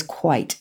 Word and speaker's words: quit: And quit actually was probably pronounced as quite with a long I in those days.